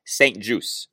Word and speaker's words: St-Juste: For the record, the Washington football team does put out an official pronunciation guide for players and coaches' names, and Benjamin's last name is to be pronounced, per the team, St. Juice.